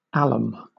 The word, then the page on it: alum: British English